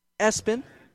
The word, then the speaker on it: ESPN: And if you were wondering, Espen, it is spelled E-S-P-N. That is what his official name is, as that pitch misses outside.